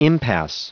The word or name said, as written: impasse